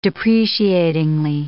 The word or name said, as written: depreciatingly